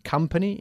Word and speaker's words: company: Company, y se pronuncia company tal y como lo acabas de hacer tú. Company y nunca company. ¿Ok? Company. Bajando un poquito la mandíbula.